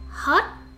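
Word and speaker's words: hót: Hot, hot. Pronounce it with a, a rising tone.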